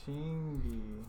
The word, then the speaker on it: Chingy: Chingy.